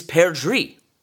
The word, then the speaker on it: perdrix: Well, it's thought that the song is of French origin, and the word for partridge in French is perdrix. spelled the same way as the word we use for the genus that partridges belong to. That word sounds a whole lot like pear tree, doesn't it?